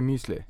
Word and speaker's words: müsli: Du tänker att han inte är müsli. – Ja, eller det är förmodligen jag som säger. – Ja, jag säger nog tyska uttalet. Liksom. – Tyska müsli.